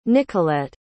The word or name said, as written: Nicolet